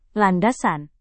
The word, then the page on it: landasan: indonesian